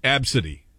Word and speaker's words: Abcde: Once you hear how it's pronounced, I think it's very evident to me that it would have to be a girl because A, B, C, D, E is pronounced Absidy.